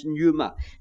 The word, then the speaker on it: pneuma: It's the same word, you see, that is used for the Spirit and also for the wind and also for the breath, a word pronounced pneuma.